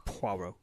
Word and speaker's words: Poirot: I really did like the opening scene of this, and I think as someone who knows really nothing going into this about Poirot, is that how you say it? – I'm sorry. – Poirot. Not like Ross Perot. Poirot. Okay.